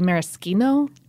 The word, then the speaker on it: maraschino: Also, I've heard that speaking of mispronouncing things and or not writing them down, I've heard that it should actually be maraschino.